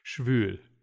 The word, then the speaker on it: schwül: There's an umlaut in schwul, which means humid. – Schwul. – Schwul. If you what say schwul homosexual. I mean, also true. – Heisen. Schwül.